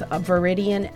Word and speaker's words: Viridian: I'm your host and my guest is Roxanne Pappas, and she is with Viridian Energy. Is that how you say it? – Yes.